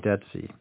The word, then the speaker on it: Dutzy: So listeners might like to know the name is spelled D-U-T-Z-Y, Dutsey.